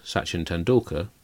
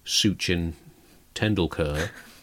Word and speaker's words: Sachin Tendulkar: And, you know, whilst traditionally people have pronounced Sachin Tendulkar's name as Sachin Tendulkar, Trump, you know, he breaks the rules, doesn't he? So he went with Suchin Tendulkar.